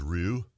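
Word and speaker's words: Ru: He asked me for my name, and I said, my name is Rue.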